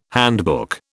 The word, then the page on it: handbook: British English